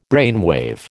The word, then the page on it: brainwave: American English